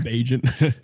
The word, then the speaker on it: Bagent: I feel like I already mispronounced, is it Bagent, right? – Isn't that how it's actually pronounced? I call him Bagent.